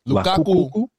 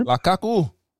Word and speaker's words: Lukaku: Romelu Lukaku. – Lukaku. Yeah. Say that again. – Lukaku.